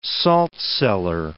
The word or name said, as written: saltcellar